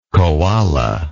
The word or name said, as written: coala